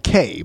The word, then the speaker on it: Cay: But there's this book called The K by Theodore Taylor. And in this case, K is spelled C-A-Y.